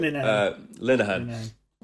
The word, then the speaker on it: Linehan: Linehan.